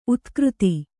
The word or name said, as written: ಉತ್ಕೃತಿ